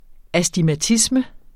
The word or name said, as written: astigmatisme